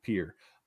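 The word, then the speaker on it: Pierre: And it's how it's pronounced in South Dakota, as God intended, where we pronounce our capital pier.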